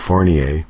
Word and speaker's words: Fournier: It's Michael Fournier, of course. Kind of a French thing.